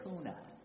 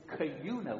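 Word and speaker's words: Kuna: Now, don't say Cuna. Don't make the mistake that I made. It took me 3 months to learn how to pronounce the name of the town that I lived in. It is Cuna, with a K. With a Q, like a cue ball, it's Cuna. And Cuna is located, in case you're wondering, about 16 miles southwest of Boise.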